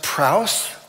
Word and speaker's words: praus: The Greek word for meek, I believe it's pronounced praus, P-R-A-U-S, which loosely translated means power under control.